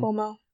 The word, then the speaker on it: Cuomo: Cuomo.